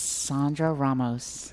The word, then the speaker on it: Sandra Ramos: Give me the correct pronunciation of your first and last name, please. – It's Sandra Ramos. – Thank you. – Consistent A's. – Sandra Ramos. – There you go. – Don't call her. – That's the Texas pronunciation.